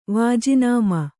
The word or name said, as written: ವಾಜಿನಾಮ